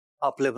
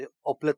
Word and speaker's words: اوپلے: اپلے بنائے جاتے تھے. اوپلے تلفظ ہوگا یا اپلے ہوگا؟ – اوپلے سنا.